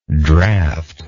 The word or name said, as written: draughter